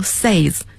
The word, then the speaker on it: says: 那么我们要注意，在说英语的过程中，say 是代表普通的动词原形，say 单数第三人称 S-A-Y-S 不能读成 says，要读成 says，发小 a 的声音，像床 bed 那个发音。所以要读成 says，这个 s-a-i-d 过去式过去分词也要读成 said as in bed，而不可以读成 sighed，大家要注意这三个词的发音。